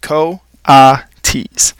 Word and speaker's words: coatis: These are coatis, Miss Brisbane said, reading from the letter pronounced co-a-tees.